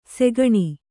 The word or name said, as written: ಸೆಗಣಿ